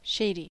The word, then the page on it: shady: American English